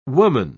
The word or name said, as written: woman